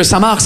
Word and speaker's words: Saint-Marc: On est d'accord les Français que Samar, ça s'écrit M-A-R-C, comme le prénom Marc. Mais vous, vous mettez un saint devant le prénom Marc, le C, il est parti en grève.